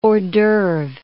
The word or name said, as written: hors d'oeuvre